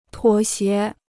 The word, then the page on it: 妥协: tuǒ xié